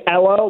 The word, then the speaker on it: Elo: Yeah, his name is Elo, like Carmelo.